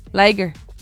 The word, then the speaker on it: liger: Les dice legres porque en español supongo que es lo correcto, ¿no? León, tigre, legre. Pero en inglés es ligre, liger, porque se escribe lion.